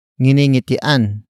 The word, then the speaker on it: niningitian: Niningitian ng mga bata ang bisita. Niningitian ng mga bata ang bisita.